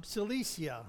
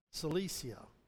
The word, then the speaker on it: Cilicia: Cilicia is the way it's pronounced. Cilicia.